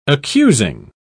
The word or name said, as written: accusing